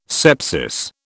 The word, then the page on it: sepsis: British English